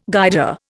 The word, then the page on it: Guider: Indian